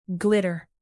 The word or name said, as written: glitter